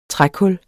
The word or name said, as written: trækul